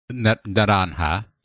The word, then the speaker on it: Naranja: It is here where players will either find the Naranja Academy, which I think is Spanish for Naranja. Yes.